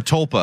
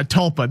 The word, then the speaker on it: tulpa: Yeah, a chalupa. Once created, a chalupa. Is that really the name? Am I pronouncing this right? A chalupa? – A tulpa, I think. – A tulpa. There we go.